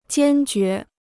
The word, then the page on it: 坚决: jiān jué